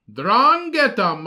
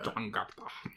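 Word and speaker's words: 'Ndrangheta: Drangheta. – See, I was right. Drangheta mafia. – Drangheta.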